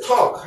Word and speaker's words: talk: talk 할 때요. 그 다음에 걷다, walk 할 때 이 L 발음 소리가 안 납니다. 그래서요, 여기 우리가 L 쓰기는 하지만 그냥 talk 하는 거예요. 얘기한다.